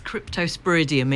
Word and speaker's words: Cryptosporidium: It's Cryptosporidium.